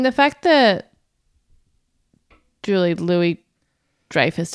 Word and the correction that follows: Julia Louis-Dreyfus: did I say that right? I always get her name wrong. Julia Louis-Dreyfus? – I think that's right.